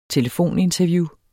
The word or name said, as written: telefoninterview